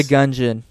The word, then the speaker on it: Gungeon: Anything else about Gungeon? Am I saying that right? Enter the Gungeon? – Enter the Gungeon. – Like a dungeon, but with guns.